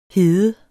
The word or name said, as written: hede